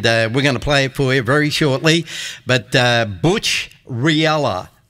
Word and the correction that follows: Rillera: it's Butch Rillera. It's spelled R-I-L-L-E-R-A. How would you pronounce that? Rillera.